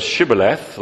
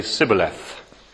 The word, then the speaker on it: Shibboleth: whether they say the word Shibboleth that way, or whether, like the people of Ephraim, they say Sibboleth. Presumably they were unable to pronounce the Shah, so when they try to say Shibboleth, it comes out as Sibboleth.